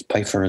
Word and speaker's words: Pipher: And that is Pifer is spelled P-I-P-H-E-R.